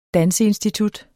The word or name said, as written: danseinstitut